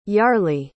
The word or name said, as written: yarely